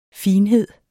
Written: FINHEDERNES

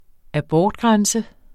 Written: abortgrænse

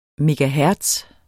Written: megahertz

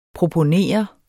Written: PROPONERE

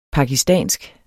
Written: pakistansk

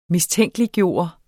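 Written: MISTÆNKELIGGJORTE

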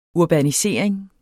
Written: urbanisering

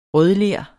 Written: rødler

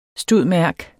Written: stud.merc.

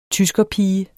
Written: tyskerpige

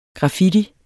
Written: graffiti

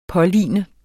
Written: påligne

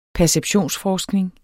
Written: perceptionsforskning

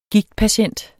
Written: GIGTPATIENT

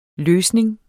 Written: løsning

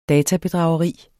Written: databedrageri

